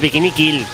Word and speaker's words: Bikini Kill: Jaime Lott nos dice que sin duda se queda con el punk rock de la banda de Kathleen Hanna, las Bikini Bill. Lo he dicho mal, ¿eh? Las Bikini Kill.